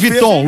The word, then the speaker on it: Vuitton: Louis Vuitton. Louis Vuitton, por exemplo. Vem do Luís XV, entendeu? – Sim. – Vuitton é 15 em francês. Claro. Louis Vuitton. – Vuitton. – Certíssimo. Fala Vuitton, mas se escreve Vuitton.